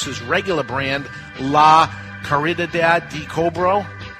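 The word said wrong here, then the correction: La Caridad del Cobre: De Cobra, yeah. That's pretty close. – What is it? – La Caridad. – La Caridad de Cobre.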